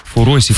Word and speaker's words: фуросики: Фуросики. – Фуросики. Ты спросишь, что это такое? Вроде правильное ударение, проверяла.